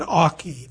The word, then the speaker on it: Avoch: It's called Aukies Spick and Aukie. That's A-U-C-H-I-E-S, Spick and Aukie, by a woman named Grace Stewart Skinner. She comes from her home village, which is spelled A-V-O-C-H, but pronounced Auk, on the Black Isle in the Scottish Highlands.